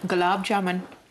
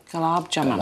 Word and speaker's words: gulab jamun: How do you pronounce it? – Gulab jamun. – Gulab jamun.